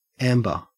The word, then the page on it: amber: Australian English